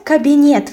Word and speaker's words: кабинет: Let's add the word кабинет for rhyme. Кабинет is cabinet in English, so in Russian, the stress falls on the last syllable.